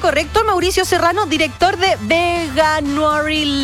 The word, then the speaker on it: Veganuary: Veganuary, Veganuary. – Ya, ok. – Hay muchas formas, todos se complican, pero mientras se entienda la esencia, es la idea. – Ya, voy con Veganuary Latam entonces. Veganuary, así.